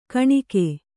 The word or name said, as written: ಕುನಿಕೆ